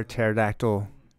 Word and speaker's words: pterodactyl: Why can't you hear pterodactyl urine in the bathroom? – Why? – Urinate in the bathroom, because the P is silent.